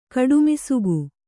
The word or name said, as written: ಕಡುಮಿಸುಗು